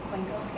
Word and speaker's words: Klinko: Last name Klinko, K-L-I-N-K-O. – Can you spell that one more time for me? – Yeah, K-L-I, and it's a Nancy K-O.